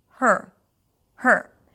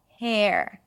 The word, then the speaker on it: her: So although there is an E there, you don't hear an E vowel sound. It's not hair. This is hair. Her, her. It's like when you say the word hurt, hurt without the T.